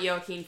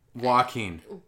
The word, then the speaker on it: Joaquin: I don't care about Joaquin Phoenix. – Joaquin. Joaquin. Don't worry.